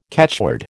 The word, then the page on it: catchword: American English